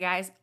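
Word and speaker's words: Gey: I'm looking at Wikipedia, guys. Let me just click on it, or it's not going to let me. – Sometimes it gives you that little pronunciation. – It's guy. Yeah, okay. It's guy.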